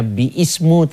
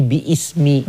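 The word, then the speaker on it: bi ismi (بسم): Jadi bacanya bukannya bi ismu tapi bi ismi. Kemudian dibuang alifnya jadi bismi, bismi.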